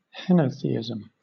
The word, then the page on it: henotheism: British English